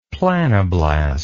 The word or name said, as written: planoblast